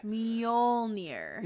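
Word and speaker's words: Mjölnir: I can never get that pronunciation down. It's like, it's Mjolnir. Okay. – Mjolnir. There you go.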